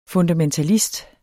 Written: fundamentalist

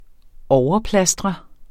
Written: overplastre

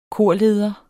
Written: KORLEDERE

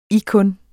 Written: ikkun

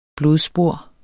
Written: BLODSPOR

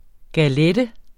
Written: galette

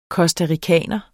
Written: costaricaner

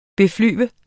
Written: beflyve

